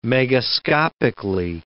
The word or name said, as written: megascopically